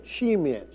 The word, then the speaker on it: Shemen: S-H-E-M-E-N. Shemen.